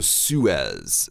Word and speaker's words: Suez: Yeah, so Suez. So this is not an English word.